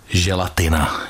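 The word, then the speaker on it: gélatine: No a jinak tik, mimovolný, neúčelný, drobný, opakující se pohyb, mrkání, záškuby, z francouzštiny přejato, ale mě zajímá ta želatina. Odkud se vzalo to slovo? – Tak je z francouzštiny, tam se vyslovuje tedy želatin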